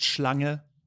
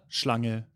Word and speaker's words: Schlange: Oh, just Schlange. – Schlange? – Schlange, yeah.